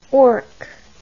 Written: ork

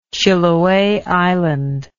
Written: Chiloe Island

